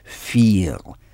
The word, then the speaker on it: feel: Yes, feel, c'est un long I. Il faut faire attention parce qu'il y a un autre verbe qui se dit to fill et qui veut dire remplir.